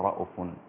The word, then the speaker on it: رؤوف: ثم تاب عليهم إنه بهم رؤوف الرحيم، كما هو عند شعبة في الأصول كلمة رؤوف تنطق رؤوف على حذف الهمز الواو هكذا: رؤوف الرحيم.